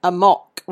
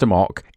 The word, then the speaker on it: amok: So, Chris is on team Amok rhymes with a lock. – It's pronounced Amok